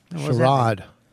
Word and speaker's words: Sherrod: Sherrod.